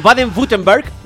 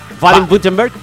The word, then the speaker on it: Baden-Württemberg: Baden-Württemberg. – Baden-Württemberg. – Sí. No lo he dicho ni parecido. Y allí, en Baden-Württemberg.